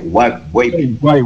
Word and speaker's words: Wave: pero se escribe UAVE, con B pequeña, WAVE debe ser Guay o número 1106, color negro, color negro mate con rojo modelo 2023. Es una moto nueva, ¿no? Sí, señor. Eh, eh, esa, eh, ¿cómo se pronuncia? ¿Guape? Guay, guay. Honda Guay